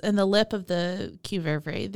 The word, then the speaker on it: qvevri: Uh, I'm gonna say it wrong again, but the qvevri. Mm-hmm. That's how that's how it's said. – That's how it, yeah qvevri. That's how it's almost exactly spelled too.